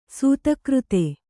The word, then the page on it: ಸತ್ಕೀರ್ತಿ: kannada